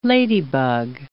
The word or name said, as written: ladybug